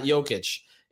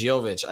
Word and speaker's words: Jovic: And first time ever, Brady, by the way, that I pronounced it as Jovic. So it's not Jokic. It's Jovic.